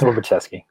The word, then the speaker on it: Orbaczewski: It's Orbaczewski.